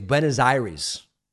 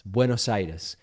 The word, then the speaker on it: Buenos Aires: I could pronounce it in these mangled American ways. Buenos Aires. Buenos Aires. It sounds almost like a disease. And it is very much not a disease, my friends. Buenos Aires.